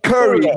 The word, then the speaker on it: curry: Curry, como el basquetbolista Stephen Curry.